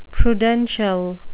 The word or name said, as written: prudential